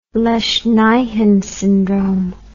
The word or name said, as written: lesch nyhan syndrome